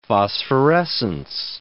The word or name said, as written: phosphorescence